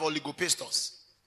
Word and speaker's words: oligopistos: Now let me spell it for you. Oligo. O-L-I-G-O. And then pist. Are you enjoying this thing? Okay. Or oligopistos. Oligopistos, O-L-I-G-O-P-I-S-T-O-S, oligopistos.